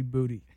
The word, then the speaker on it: Kayshawn Booty: Kayshawn Booty. That's the actual pronunciation. – I like calling him Booty, though. – It's actually Booty.